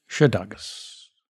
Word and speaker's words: Szydagis: Here now, my conversation with Matilda Szydagis.